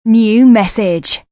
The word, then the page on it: New Message: British English